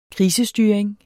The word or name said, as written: krisestyring